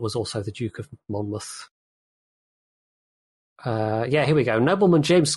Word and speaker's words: Monmouth: Monmouth. – Monmouth. – Yeah, it's Muth rather than Mouth. – Okay. – Even though it looks like Mouth.